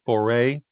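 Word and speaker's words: Foret: Last name is, I think it's pronounced Foray. It's spelled F as in Frank, O as in Oscar, R as in Rufus, E as in Ego, T as in Tree. Tracy Foray.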